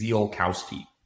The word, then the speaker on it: Ziolkowski: I believe it's pronounced Zielkowski, but lose you? – The Americanized pronunciation is Zielkowski.